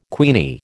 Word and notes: queenie: American English

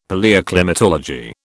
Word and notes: Palaeoclimatology: British English

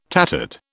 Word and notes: tattered: British English

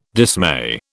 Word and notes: dismay: British English

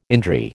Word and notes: indri: American English

